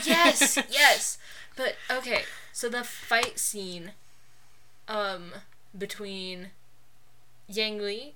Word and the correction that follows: Ying Li: is that how you pronounce it? And Wenwu. – Ying Li.